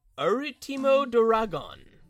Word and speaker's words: Ultimo Dragon: It's just like how it's pronounced in Japanese, or not in Japanese, but how Japanese people pronounce it, which is Arutimo Doragon.